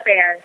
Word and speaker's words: Fair: It's Alexa Fair. – Fair! – It's a weird spelling of it, but yeah, it's pronounced Fair. – Oh, good.